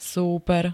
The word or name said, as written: súper